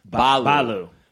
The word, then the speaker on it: Baloo: Baloo is actually pronounced Balu.